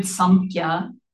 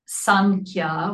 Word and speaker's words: Samkhya: And the Sanskrit word Samkhya, sometimes it's pronounced Sankhya with an N instead of an M.